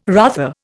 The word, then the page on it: rather: Indian